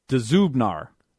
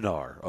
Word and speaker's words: Dzubnar: Let me say Dzubnar. – I said Zubnar or something. – Yeah, on the Titans official website. – Okay. – They have Dzubnar is how you say it. – Dzubnar.